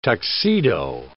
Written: tuxedo

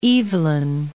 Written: Evelyn

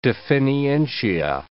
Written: definientia

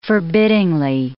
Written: forbiddingly

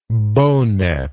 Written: bow net

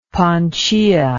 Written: Panchaia

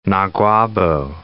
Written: Naguabo

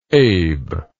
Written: abe

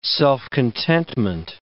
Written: self-contentment

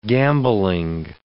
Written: gambolling

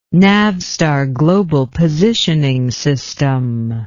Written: navstar global positioning system

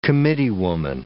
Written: committeewoman

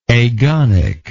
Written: agonic

